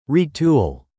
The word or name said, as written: retool